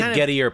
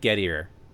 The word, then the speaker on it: Gettier: Just to be clear, I was ripping off of some guy named Gettier. – A rabbit hole. – Whose real name is Gettier, but he pronounces it Gettier because he's an American idiot.